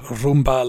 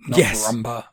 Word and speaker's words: rumba: Also, it is pronounced rumba, like the vacuum cleaner, not rumba.